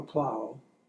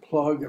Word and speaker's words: Plaug: Otto Plaug. And it's spelled P-L-A-U-G. And he was very annoyed that people would pronounce it plug and plug.